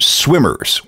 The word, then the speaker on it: SWMRS: And then the group changed their name to Swimmers, which is spelled S-W-M-R-S.